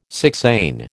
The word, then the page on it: sixain: American English